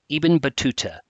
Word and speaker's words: Ibn Battuta: That person's name is spelled I-B-N space B-A-T-T-U-T-A. Ibn Battuta. His book is called The Rilla, R-I-H.